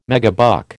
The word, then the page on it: megabuck: American English